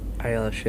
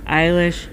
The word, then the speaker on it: Eilish: How do I say that last name? Eilish? – Eilish, yeah. – Eilish.